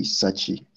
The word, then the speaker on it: Satsi: So Julius, my name is Julius Kofi Sechi. It's spelled S-A-T-S-I. It's not a normal Sechi that you know.